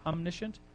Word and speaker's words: omniscient: He's om, omniscient. He knows. Is that how you say it? Omniscient. Yes.